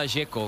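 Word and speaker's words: Džeko: Porque DZ, ¿cómo se pronuncia? – Deco. Bruno Dzeko. Dice que es Deco. – Como tirando a J, Dzeko o algo así.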